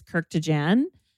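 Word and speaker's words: Kurkdjian: We're going to be talking about Fleur Missing Person, Maison Francis Kirkwood. Oh, how do you say this one? Help me out. – Maison Francis Kirk de Jan, Baccarat Rouge 540.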